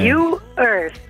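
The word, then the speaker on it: U-Earth: Amministratrice delegata di, ci aiuti a pronunciarlo, come You Earth, come U trattino Earth come terra.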